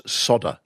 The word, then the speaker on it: solder: And for some reason, they've decided to pronounce things differently, just as in American English, solder is sodder for some reason. They just leave out the L.